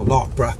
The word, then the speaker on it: Larkbarrow: called Larkbarrow, or Larkborough, as locals pronounce it, used it for target practice and blew it to bits.